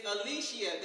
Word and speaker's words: Aleechea: My name is Alicia. That is spelled A-L-E-E-C-H-E-A.